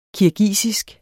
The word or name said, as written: KIRGISISK